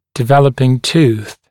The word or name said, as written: developing tooth